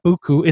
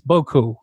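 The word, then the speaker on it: beaucoup: It's not Buku, it's Boku.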